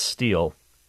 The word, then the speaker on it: stele: And what that means is it's spelled S-T-E-E-L-E, but it's pronounced stele. Like the man of steel.